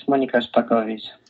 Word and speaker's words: Monika Szpakowicz: It's Monika Szpakowicz.